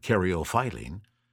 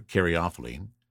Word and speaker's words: caryophyllene: And now we come to caryophyllene, and I have also heard an alternate pronunciation of this, so I'll throw that out as well, and caryophyllene. So caryophyllene or caryophyllene. Either way, it's best known for its spicy and peppery note. I prefer caryophyllene.